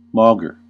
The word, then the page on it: bien que: [bjɛ̃ kə]